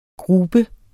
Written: grube

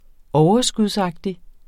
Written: overskudsagtig

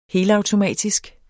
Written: helautomatisk

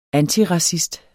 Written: antiracist

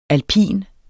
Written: alpin

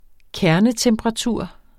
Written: kernetemperatur

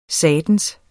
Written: satans

